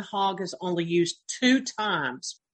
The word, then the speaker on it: chag: It also comes from a form of the word hog. And so hog is what you see on the screen here, C-H-A-G. That's how you pronounce it in Hebrew.